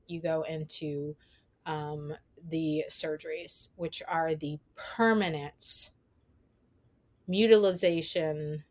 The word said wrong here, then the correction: mutilation: and sterilization of, or mutilation, I said that wrong, mutilation and sterilization of the children's bodies.